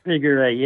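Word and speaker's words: Viguerie: Am I saying that right? Vigouré? – Vigouré, yeah.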